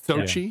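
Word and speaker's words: Xochitl: I cannot pronounce her first name. – Is it Sochi? Sochi Gomez. – Sochi. Yeah. – Yes.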